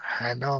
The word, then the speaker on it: هانا: تلفظ اسم کوچیکش هم هنا میگن، هانا میگن، بیشتر هانا اینجا، که خانم هستش، که خانم مخبری بود.